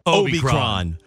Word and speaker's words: Omicron: And the correct way to say this is Omicron.